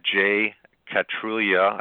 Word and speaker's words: jkatrulya: You're offering anybody who contacts you on LinkedIn or emails you at jcatrulia. I hope I'm pronouncing that right. Did I get that right, or am I Absolutely. – Butchering it? – Yes. – Okay. – Thank you. – J-K-A-T-R-U-L-Y-A.